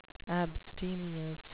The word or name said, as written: abstemious